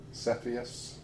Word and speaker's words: Cepheus: Cepheus. – How do you spell it? – C-E-P-H-E-U-S.